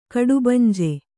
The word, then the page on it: ಕಡುಬಂಜೆ: kaḍubanje; Kannada